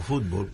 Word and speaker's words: fútbol: pero en el mundo entero se conoce como fútbol, con acento en la U, fútbol.